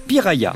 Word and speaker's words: piraya: Dans leur langue, le tupi guarani, cela se prononce piraya, puis Piraya est devenu Piranha en portugais.